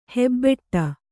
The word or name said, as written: ಹೆಬ್ಬಟೆ